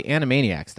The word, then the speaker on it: Animaniacs: you need to know about the Animaniacs.